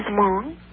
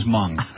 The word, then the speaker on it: Hmong: I would like to know, what in the world is Hmong? – Good. – What is Hmong? Did you see the scene where they were scraping the leeches off their bodies? – I saw that. – That was Hmong. – What? How do you spell it? – M-U-N-G.